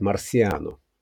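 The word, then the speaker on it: Marciano: Так на свет появился Рокки Марчиано. Кстати, в Америке его фамилию произносят Марсиано.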